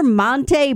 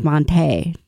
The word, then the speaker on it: Monte: Remember Monte Ball, Jonathan? Does that name ring a bell? – Sure. – The Wisconsin football, he changes it. The pronunciation is Monte.